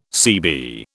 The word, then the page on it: CB: British English